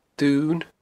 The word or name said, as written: Dun